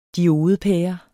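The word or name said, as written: DIODEPÆRE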